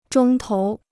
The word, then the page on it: 钟头: zhōng tóu